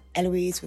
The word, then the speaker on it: Heloise: And uh, yeah, just find me Heloise Nangle, uh, Heloise with a silent H.